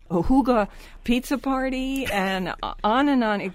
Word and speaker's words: hygge: And don't forget, people, it's spelled, The Hygge Life is the name of the book, and it's spelled H-Y-G-G-E, but you pronounce it in the language Huga. You know, H-O-O-G-U-H, Huga, like that.